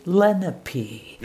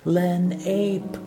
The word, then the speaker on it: Lenape: My mind wants to call the first Algonquin Native Americans in the Flemington area Lenape. – No. – However, some locals tell me it's pronounced Lenape.